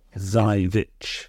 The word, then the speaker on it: Żywiec: It is pronounced, Żywiec is pronounced Żywiec. – Żywiec. – Is the Polish pronunciation.